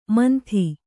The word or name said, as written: ಮಂದ